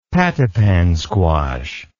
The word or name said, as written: pattypan squash